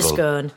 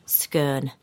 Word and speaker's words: scone: And a scone. Pronounced scone. Or scone, if you're posh. – Scone if you're Lancashire. – Oh, is it? I thought scone was posh. – No, scone is posh.